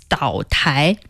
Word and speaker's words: 岛台: Island means 岛, but it's not an actual island. Still some version of a counter. Mm. So it's Dao Tai.